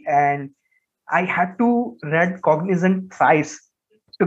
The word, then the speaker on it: Cognizant: I guess that when Aston Martin announced that they have a brand new title sponsor in Cognizant. Pardon me if I'm pronouncing it wrong. That is Cognizant. Yeah, it's correct. Cognizant.